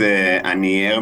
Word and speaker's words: Anier: So the name is Anier Mechina. Mm-hmm. It sounds a bit French because yes. It's a name of a small town in close to Geneva in mm-hmm.